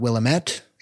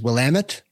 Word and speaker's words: Willamette: It is not Willamette, it is Willamette. – Willamette. – It rhymes with damn it, and that's how you know.